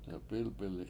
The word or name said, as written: delp7Ilp7ili7